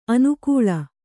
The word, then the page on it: ಅನಾಕುಳ: anākuḷa